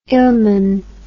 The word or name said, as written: ilmen